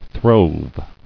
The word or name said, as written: Throve